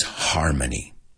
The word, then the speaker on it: harmony: And the last one is harmony. Armonía. Harmony. Vamos a pronunciar esta juntos, porque la H se pronuncia en inglés. Harmony.